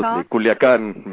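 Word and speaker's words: Culiacán: Absolutely, Culiacan, Mexico, yes.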